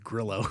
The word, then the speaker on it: Grillo: Emiliano, yeah, well, I think it's the Australian, the Argentinian pronunciation of a double L is je, I believe. The Spanish is a Y sound. And then he went ahead and just told Americans to just go with Grillo because he's like, y'all can't do this.